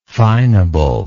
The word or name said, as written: fineable